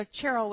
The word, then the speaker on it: Cheryl: And so Cheryl with the hard CH is the Hebrew or the Jewish way to pronounce her name.